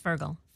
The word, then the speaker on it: Fergal: Okay, so we're going with Fergal. Fergal Sharkey. Yes. – Please tell me about. So this is Fergal Sharkey is.